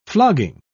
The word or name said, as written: flogging